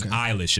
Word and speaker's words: Eilish: Eilish, but it is Eilish, apparently.